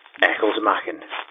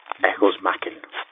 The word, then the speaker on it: Ecclesmachan: It's pronounced Ecclesmachan, but I will accept Ecclesmachan.